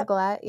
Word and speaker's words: galette: Am I saying it right? – Apple Eva? Galette, yeah.